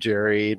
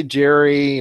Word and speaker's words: Jerry: No, with a J, you know, J-E-R-R-Y, you know, just Tom and Jerry like that, you know, like the mouse and all that.